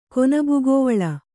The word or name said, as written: ಕೊನಬುಗೋವಳ